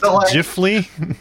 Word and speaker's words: Ghibli: Actually, it's pronounced Ghibli.